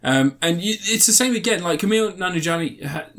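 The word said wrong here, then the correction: Nanjiani: Nan Nan Nanajani, Nan. I keep pronouncing his name wrong. How do I pronounce his name, Ant? Come on. Camille Is that it? Camille. Yeah. Nanjani.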